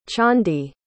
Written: tchondee

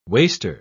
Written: waster

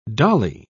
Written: dolly

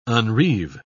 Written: unreeve

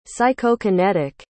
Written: psychokinetic

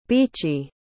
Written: beechy

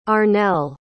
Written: Arnel

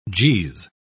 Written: geez

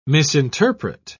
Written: misinterpret

